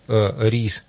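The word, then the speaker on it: riz: По-французски рис. Ри. Последняя просто буковка не читается, а на самом деле даже пишется рис. Там только Z идет.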